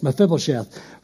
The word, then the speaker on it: Mephibosheth: Well, I've practiced it. Don't worry, I'm a professional. I know how to say it. Mephibosheth.